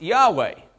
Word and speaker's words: Yahweh: The closest we can come to in English is Y-H-W-H, which is pronounced Yahweh, basically.